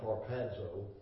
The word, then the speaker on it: harpazo: Strong's concordance in studying this, the Greek word harpazo, it's actually pronounced harpazo, it's used 13 times.